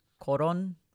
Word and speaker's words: Collon: It's spelled C-O-L-L-O-N. And for anyone who's not, you know, familiar with this snack, I believe in Japanese it's pronounced koron, but that's kind of the way it translates.